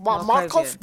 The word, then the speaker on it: Markov: Markov. M-A-R-K-O-V. Markov chains.